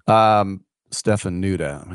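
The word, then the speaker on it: Ștefănuță: So now this guy, Stefanatu, sounds very Romanian, if I can go there, Nicolai Stefanatu. Stefanuta, I said it wrong again, live radio.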